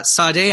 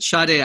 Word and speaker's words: Sade: But today, we're talking about the Sade album, Diamond Life. – Yeah. – Sorry, Sade. I said it wrong the first time I said it. Sade album, Diamond Life, just from 1984.